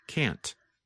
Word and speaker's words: can't: And if you are speaking and want to be very clear, simply pronounce the letter T, like can't.